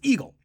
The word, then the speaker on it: EGLE: colloquially referred to as EGLE, and or EGLE, and it's pronounced as EGLE.